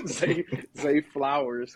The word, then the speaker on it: Zay Flowers: Zay Flowers has been incredible.